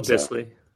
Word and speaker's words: Dissly: I don't know how to say his last name. – Will Disley. So. – Disley, yeah, okay, there it is.